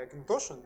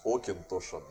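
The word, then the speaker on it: Auchentoshan: Как это правильно вообще произносится, потому что Да, ты застал непонятно. момент, когда они переходили с Окин Тошин на Акин Тошин.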